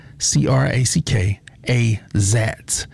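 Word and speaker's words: Crackazat: And here's a song that I've been loving for the past couple weeks, Crack-A-Zat. I always say it wrong. It's Crack, C-R-A-C-K, A-Zat. A-Z-A-T. That's C-R-A-C-K-A-Z-A-T. I think that's Crack. Yeah, Crack-A-Zat.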